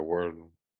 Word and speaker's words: Wordle: Like Wordell? – Word with an L-E on it. – Oh, like Wordle.